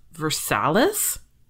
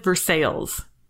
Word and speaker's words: Versailles: No, I mean, like, when I was told, like, you're doing it wrong, I was like, is it supposed to be pronounced Versalles? Like, that's what it looks like to me. Like, it doesn't look like Versailles.